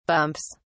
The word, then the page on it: bumps: British English